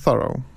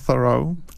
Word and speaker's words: Thoreau: Natomiast warto wspomnieć, że Amerykanie i najpewniej sam Thoreau wymawiał swoje nazwisko Thoreau z akcentem na pierwszą sylabę. Tak. Wielu angielskich profesorów wymawia Thoreau z akcentem na drugą sylabę, co jest takim kompromisowym, bym powiedział, wariantem między Thoreau a Thoreau.